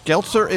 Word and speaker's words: Geltzer: Josh Geltzer is our special guest. Rhymes with Seltzer, Deputy National Security Advisor for Homeland Security and the National Security Council.